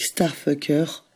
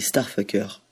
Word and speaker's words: STRFKR: On continue avec Starfucker, qui s'écrit désormais S-T-R-F-K-R,